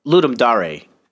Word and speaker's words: Ludum Dare: It's a it's a it's a very good uh, entry for Ludum Dare. Ludum Dare. Sure, it's Dare. – Yeah, that's what how it's how they say it.